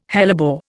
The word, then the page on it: hellebore: Indian